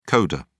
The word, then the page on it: coder: British English